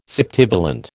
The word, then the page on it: septivalent: American English